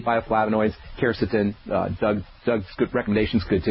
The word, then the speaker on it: quercetin: There's a bioflavonoid actually that's really has a lot of literature that supports its use as an antihistamine, and that is something called quercetin, and that's spelled Q-U-E-R-C-E-T-I-N, quercetin.